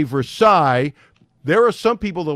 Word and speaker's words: Versailles: Versailles, you know how I pronounced it? Versailles. – Exactly. – And I said, where is Versailles? And somebody in the newsroom said, hey, and corrected me.